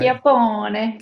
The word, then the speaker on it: Chiappone: Chiappone. Chiappone. It's in Italian.